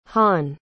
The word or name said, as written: hahn